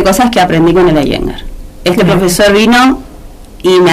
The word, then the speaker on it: Iyengar: ¿Cómo es que se pronuncia? – Iyengar. – Iyengar, así aprendo. – Se escribe Iyengar. – Y yo digo así, pero pero se pronuncia Iyengar.